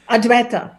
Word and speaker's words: Advaita: You are also a teacher, if you will, or sort of a master of if I pronounce this correctly, Adyavita. – Ayurveda. – Yes. Well, no, not, yes, Ayurveda, but also of the non-dualism. – Advaita. Yes, you got it.